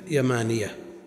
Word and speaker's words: يمانية: فقالوا ينطق بتخفيف الياء يمانية، الإيمان يمان والحكمة يمانية، وجوز بعضهم التشديد لكن رد عليه.